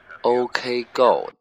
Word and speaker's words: OK Go: 行了，这首歌马上就要完了，咱们听一下美国前两年比较火的一个叫 OK Go，我敢肯定这个发音没有读错，OK Go 的 Let It Rain，就是让它下雨吧。